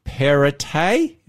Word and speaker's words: Perrottet: And so Dominic Perrottet, is that how you pronounce it? – Yep.